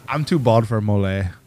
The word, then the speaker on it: Mollet: MOLLET, Mole. – So we got to pronounce it mole.